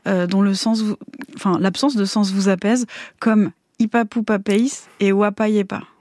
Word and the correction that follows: Wapasiepas: Wapasiepas. – On prononce le S.